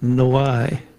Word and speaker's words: Noailles: For instance, and this one's hard to pronounce because it's French name, N-O-A-I-L-L-E-S, and the family pronounces it Noy, and it's used as a middle name and such.